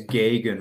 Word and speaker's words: Gegen: The correct way to say it is Gegen, like Gegen pressing.